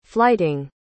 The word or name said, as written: flyting